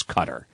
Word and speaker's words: Qatar: You know, it's spelled Q-A-T-A-R, and some people say Qatar. I thought the proper pronunciation was cutter. I mean, that's been brought back and forth. I think both are acceptable, but I think it's cutter.